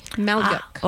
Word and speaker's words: Malyuk: Nope. M-A-L-Y-U-K. Maluk.